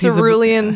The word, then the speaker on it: Cerulean: Because Cerulean. He's a bl- uh-huh, oh, uh-huh, that's uh-huh, funny. Uh-huh. – Cerule- but it's spelled like like there's two U's and oh. you're supposed to pronounce both of them. So it's like cerulean.